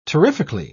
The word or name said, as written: terrifically